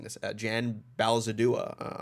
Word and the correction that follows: Bazaldua: on art as well. – Jan Bazaldua, yeah.